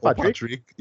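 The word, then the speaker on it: Pádraic: Well, I just seen that word, but you know, Irish words is like a whole pronunciation. Padraic, you know? I assume. – Patrick. Yeah. Yeah.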